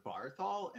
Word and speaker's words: Barthol: It's Christopher Barthol, and my last name is spelled B as in boy, then A-R-T-H-O-L.